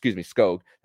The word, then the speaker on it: Skog: Wilmer Skog was Skog, that's how you pronounce it, according to Doug Plagans on the broadcast.